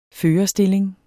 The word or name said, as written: førerstilling